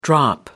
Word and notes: drop: American English

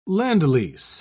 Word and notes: lend-lease: British English

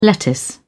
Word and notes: lettuce: British English

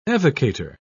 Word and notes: evocator: British English

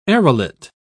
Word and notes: arillate: British English

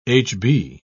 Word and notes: hb: British English

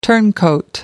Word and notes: turncoat: American English

